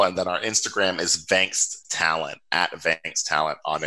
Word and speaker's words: Vangst: Yeah, so correct pronunciation is Vangst. It's Dutch for catch. Dutch for to catch.